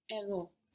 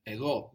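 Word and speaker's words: Ego (ἐγώ): Where is the stress on these words? Ego, Edo. – At the end. – At the end, good.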